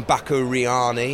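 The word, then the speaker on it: Bakuriani: From Baku Riani in Georgia. That was last... – Good pronunciation. – Thank you very much. – You did well.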